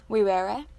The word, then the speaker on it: vivere: And if you want to know how that's spelled, it's V-I-V-E-R-E. V-E-R-E, because in Latin, V's are not pronounced with such a hard pronunciation. They're pronounced more like W's.